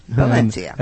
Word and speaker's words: Valencia: With a C pronounced TH. – And the V is B, Valencia.